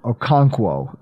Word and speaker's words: Okonkwo: I'm not going to pronounce his first name, but it's Okwunu. – Okonkwo. – Okonkwo? – Yes.